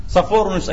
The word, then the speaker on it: صفورنيوس: خرج صفورنيوس أيها الإخوة، هكذا ينطق باللاتينية والعرب طرق كثيرة في نطقه،